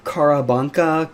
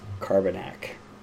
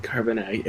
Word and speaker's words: Carbanak: I was like, Carabanka, Carabinka, Carbanka. Just couldn't get it. But I finally figured out it was Carbanak. – Carbanak.